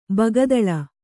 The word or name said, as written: ಬಕ್ಕತಲೆ